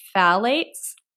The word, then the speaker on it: phthalates: They contain things like phthalates, maybe. It's a pH word. It's a PHTH word. So it's a tricky one. Yeah. – I think you're actually saying that right.